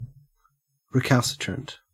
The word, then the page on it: recalcitrant: Australian English